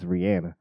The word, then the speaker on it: Rihanna: Yeah, she's letting niggas know that how to pronounce her name correctly. Mm-hmm. It's Rihanna.